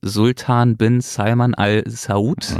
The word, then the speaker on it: Sultan bin Salman al-Saud: ist Sultan bin Salman al-Saud.